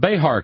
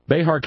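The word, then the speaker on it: Bexar: It's Behar County, Texas, isn't it? We pronounce the X as an H. Behar County, Texas detention officer was taken to the hospital Tuesday after he was injured when his gun accidentally discharged.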